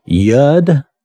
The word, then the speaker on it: Yod: It's spelled Y-O-D, so we might be tempted to say Yod or Yod, but from what I could look up and tell, it's pronounced Yud.